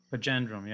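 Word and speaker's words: Panjandrum: They tested how feasible it was, how do you pronounce this? Panandrum? Pajandrum? – Pajandrum, yeah.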